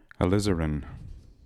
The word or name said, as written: alizarin